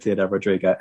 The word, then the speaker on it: Ciudad Rodrigo: This is exactly where we get Ciudad Rodrigo and Badajoz. I've just done that thing of going, here's the Spanish pronunciation, and I'm going to be hip and millennial and use the Spanish pronunciation.